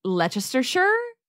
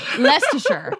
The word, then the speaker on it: Leicestershire: I don't know if I'm pronouncing this, Leicestershire. Am I saying that right? – No. – Leicestershire. Okay, so it's Leicestershire, but